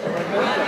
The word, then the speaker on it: Arundel: Arundel, I think is that how you say it? – Arundel. – What? – Arundel.